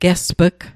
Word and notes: gesp'g: Mi'gmaq

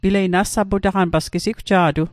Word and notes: nassaputaqan: Mi'gmaq

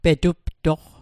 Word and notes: petuptoq: Mi'gmaq

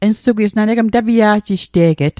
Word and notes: tapia'ji'jte'get: Mi'gmaq

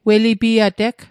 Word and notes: welipiateg: Mi'gmaq

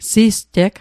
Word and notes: sisteg: Mi'gmaq

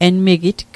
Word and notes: enmigitg: Mi'gmaq

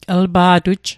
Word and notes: lpa'tuj: l'pa'tuj; Mi'gmaq

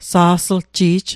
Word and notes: sa'slji'j: Mi'gmaq

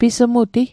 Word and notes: pismuti: Mi'gmaq

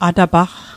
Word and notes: atapaqq: Mi'gmaq

